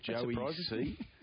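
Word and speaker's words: Jowi C: Joey C. – Joey C. – And it's spelled J-O-W-I. J-O-W-I-C. Joey okay. C. So a bit of play on words there and letters.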